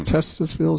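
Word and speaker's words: Titusville: First successful oil well drilled by Colonel Edwin Drake near Tustisville, Pennsylvania. – Hmm. – Testosville, is how you say that.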